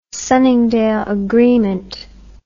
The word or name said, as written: Sunningdale Agreement